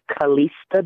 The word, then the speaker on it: Calesta: You can hit my professional page, which is Kalista Day. It's spelled C-A-L-E-S-T-A, and last name is D-A-Y.